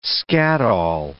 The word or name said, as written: skatol